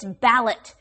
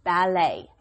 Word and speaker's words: ballet: But obviously, the French pronunciation, it sounds a little bit nicer, too. They would say ballet. – Okay, the T is silent. You don't pronounce the T because it's a French word, ballet.